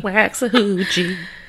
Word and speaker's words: Waxahachie: So the trial officially started in September of 2019 in Waxahachie, Texas. – Probably. Which, probably not saying that correctly, but you know how that goes for me. – Waxahachie.